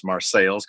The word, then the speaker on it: Marseilles: But that's just me in front of the ancestral Morello home in Marseilles, Illinois, spelled like Marseille, France, pronounced Marseilles because it's in central Illinois.